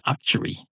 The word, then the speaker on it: actuary: It told me all about insurance assessors, but didn't say anything about it being actuary. You arseholes. Actuary. That's what it is. It's actuary. Rhymes with factory, which probably annoys a lot of people.